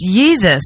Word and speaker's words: Yeezus: No, and I won't because, first of all, pronounce it right. – It's Yeezus.